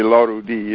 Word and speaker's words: Larudi: And he tried to get people to pronounce it with the accent on the last syllable, as the Iranian sound system is. So it would be Larudi is the correct pronunciation.